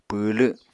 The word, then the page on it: pwwlw: Apatani